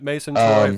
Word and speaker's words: Mason Toye: I can't remember the exact position, maybe 5th position, Mason Toye. Am I saying that right? Or yep. Toye? – Yep, Mason Toye, forward.